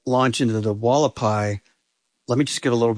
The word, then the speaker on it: Hualapai: The Hualapai.